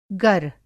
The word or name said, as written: गर